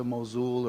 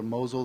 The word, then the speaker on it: Mosul: Then we moved up to Mosul, or Mosul, the locals say it both ways.